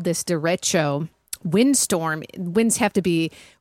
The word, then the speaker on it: derecho: How do you go about pronouncing it in English? Derecho. – Derecho. Derecho. Derecho. So this is what I'm doing. Jason Aldean's playing and I'm like, derecho. It's a Spanish word.